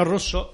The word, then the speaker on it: rosso: regu esto, Durante el bienio rosso. – ¿Cómo pronuncias esa R inicial? – Rosso.